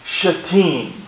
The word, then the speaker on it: Shittim: The way it's pronounced is Shittim.